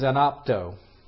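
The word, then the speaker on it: anapto (ἀνάπτω): And that word that's underneath the idea of being of set aflame is anapto. Anapto, that's the Greek word that's there. Anapto. A-N-A-P-T-O, long O, anapto.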